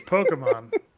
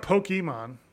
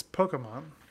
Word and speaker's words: Pokemon: It's Pokemon. It's Pokemon. – Yeah. – It's not Pokemon. It's Pokemon.